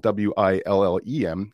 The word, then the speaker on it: Willem: And you might have seen that his name is uh, uh, spelled W I L L E M. Uh, well, Willem's actually from South Africa, and the pronunciation uh, uh, there is is Willem.